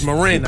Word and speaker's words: Marin: Excuse me, it's Marin. I think they pronounce it Marin out there, Marin County.